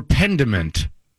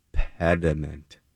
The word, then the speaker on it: pediment: Is it pediment or pendiment? – Pediment.